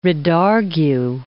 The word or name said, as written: redargue